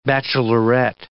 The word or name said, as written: bachelorette